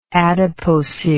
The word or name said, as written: adipocere